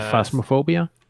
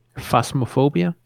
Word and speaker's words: Phasmophobia: I guess there's always spooky games, but this game you're playing, and you can probably pronounce the name way better than I can. – Yeah, Phasmophobia. – Can you say it again? – Phasmophobia. – Phasmophobia? Okay. – Yeah.